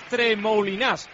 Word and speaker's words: Tremoulinas: que es el francés Tremoulinas. Creo que se pronuncia así, salvo que Pedro Martín. Salvo que Pedro Martín me corrija, que lo hará. – Se pronuncia así, correctamente.